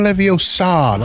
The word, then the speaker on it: Leviosa: So Wingardium Leviosa, not Leviosa.